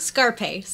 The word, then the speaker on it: Scarpace: Scarpace.